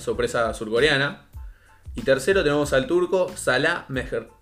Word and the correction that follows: Mejri: Espero estar pronunciándolo bien. Mejri. Mejri, sí.